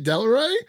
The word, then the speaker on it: Delroy: Uh, uh Delroy Lindo, that did I did I butcher his name? is it Delroy? Yeah. It is okay. I almost said Roy Del Lindo. I'm like, that is not correct.